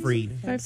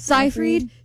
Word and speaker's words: Seyfried: It was about Amanda Seyfried. Is that how you say her name? – Seyfried. – Seyfried.